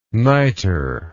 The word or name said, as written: nitre